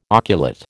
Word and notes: oculate: American English

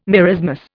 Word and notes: marasmus: Indian

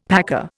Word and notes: paca: Indian

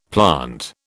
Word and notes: plant: British English

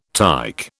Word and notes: tyke: British English

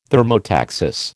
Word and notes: thermotaxis: American English

